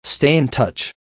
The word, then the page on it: stay in touch: American English